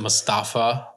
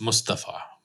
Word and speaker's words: Mustafa: Yeah, some people may pronounce it Mustafa, like kind of like Mufasa. – Yeah, like, but I know that the proper pronunciation is Mustafa.